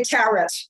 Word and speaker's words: tarot: I'll say tarot. Like cat. No. Not a parrot like tarot. No. Or not tarot like parrot.